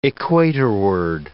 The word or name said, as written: equatorward